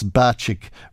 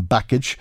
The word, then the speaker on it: Bacik: Pat McDade calling into question my pronunciation of the Labour leader's name. He says it's Batchik rather than Backage.